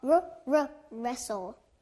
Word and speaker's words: wrestle: R-R-Wrestle R-R-Wrestle R-R-Wrestle Very good!